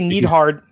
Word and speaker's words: Neidhart: How do you say it? I say Neidhart. Yeah. And it's spelled like my last name with the E-I.